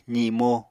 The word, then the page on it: zimo: apatani